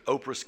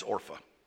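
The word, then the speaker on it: Orpah: The title of the message tonight is Oprah's Kiss. And actually, if you're going to pronounce her name right, it's Orpha.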